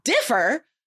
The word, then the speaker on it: differ: Differ, syllable, first syllable is stressed, and defer, that's like a, it's a different verb.